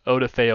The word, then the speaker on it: Odafe: Odafe. Odafe. Odafe. is the way Odafe.